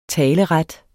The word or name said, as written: taleret